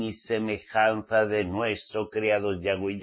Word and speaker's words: Yahweh: Miren, sobre YHWH, vuestros líderes mesiánicos de pacotilla o judíos mesiánicos no les han enseñado que la pronunciación es Yahweh. Que la orden de nuestro creador,